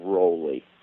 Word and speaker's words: Rowley: So if they'll just search The Healthcare Blues, Tom Rowley, R-O-W-L-E-Y, it'll probably come right up.